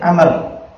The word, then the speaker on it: Amr: Nah, saya Amr dikasih wawu dan curian dari Dawud, makanya apa? Tidak bunyi di sini, dia sembunyi. Nah, saya disembunyikan, tidak dibunyikan untuk membedakan dengan Umar. Nah, kalau tanpa wawu kita bacanya Umar.